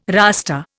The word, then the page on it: Rasta: Indian